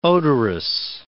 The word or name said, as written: odorous